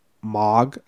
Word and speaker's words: Mog: Because in Britain, Margaret, shortened to like Mog, how would you say it? Like they spell it M-O-G. – Margaret. – Yeah, so shorten that with... – Marge. – No, it's not Marge, it's Mog. – You're saying Mog as an M-O-G.